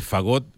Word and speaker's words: fagot: El fagot es como una trompeta, sí. – En inglés es bien feo lo que significa. – Como clarinete, fagot, pues. – Pero es con acento en la A.